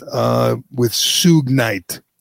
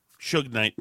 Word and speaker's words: Suge Knight: Michael Harris, co-founder with Suge Knight. How do you say it? – Suge Knight. – Suge Knight. Yes.